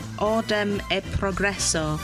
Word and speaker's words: Ordem e Progresso: That is Ordem e Progresso, which is wonderful Portuguese pronunciation.